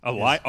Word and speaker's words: Alioth: Alioth? – Alioth.